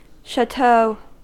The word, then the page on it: chateau: American English